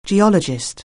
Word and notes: geologist: anglais

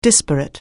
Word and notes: disparate: anglais

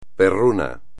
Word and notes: perruno: espagnol

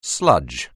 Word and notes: sludge: anglais

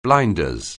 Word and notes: blinders: anglais

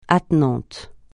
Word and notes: attenant: francais

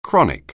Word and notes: chronic: anglais

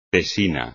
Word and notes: tesina: espagnol

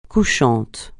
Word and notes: couchant: francais

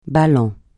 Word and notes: ballant: francais

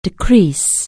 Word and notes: decrease: anglais

